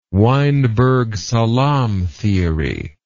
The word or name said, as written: weinberg salam theory